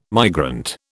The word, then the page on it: migrant: British English